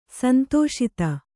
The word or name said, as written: ಸಂತೋಷಿತ